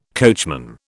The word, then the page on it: coachman: British English